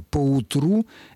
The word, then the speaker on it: поутру: Поутру — это наречие, которое пишется слитно, и ударение имеет, как мы видим, на последнем слоге.